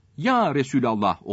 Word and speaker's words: Resulallah: Resulullah ya ile başlayınca ya Resulallah olur. Rabbül Alemin ya ile başlayınca ya Rabbel Alemin olur.